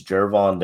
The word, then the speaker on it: Gervon: But we'll get into the second round, and our first pick was is it Jervon or Gervon? It's spelled with a G, but I believe it's Jervon Dexter out of Florida, defensive tackle.